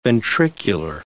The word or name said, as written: ventricular